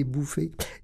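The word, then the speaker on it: Bouffay: C'est bien comme nom, hein? Mais ça s'écrit F-F-A-Y, quartier Bouffé.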